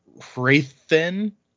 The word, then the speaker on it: Hrathen: So I'm going to say Raoden because that's how I say it in my head, and Hrathen, even though technically it's Raoden and Hrathen,